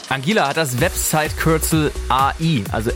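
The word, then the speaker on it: Anguilla: Angela. Habe ich es richtig gesagt, Simon? – Ja, würde ich sagen, weil es tatsächlich zu Großbritannien mit dazu gehört.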